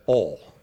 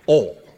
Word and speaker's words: oil: You got to put oil in the engine, or as my wife from Texas likes to say, oil. It's not a single syllable in Texas. It's a half syllable. Oil.